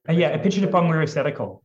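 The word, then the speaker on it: Apichatpong Weerasethakul: Epichitupong Weerasethakul.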